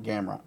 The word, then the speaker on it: Gamrot: Jeremy Stevens and Gamera, or how do you say his name? – I'm just calling him Gamrot. – Gamrot? – It's Gamrot.